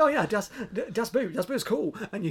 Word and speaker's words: Boot: And yes, I pronounced that correctly, because it is actually, boot is pronounced boat, so it is actually called Das Boot.